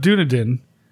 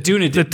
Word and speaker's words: Dunedin: The city of Dunedin is like the Dunedin. No, you're saying it wrong. – The Dunedin. – Dunedin. – Dunedin. – Right.